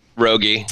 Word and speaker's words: Rogge: That comes from Raj or Rogi. I'm not sure how to pronounce it. R-O-G-G-E. – Rogi. Yeah, Rogi. Mike Rogi.